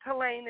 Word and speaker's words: Helene: It's Helene. It's like Elaine with an H in front of it.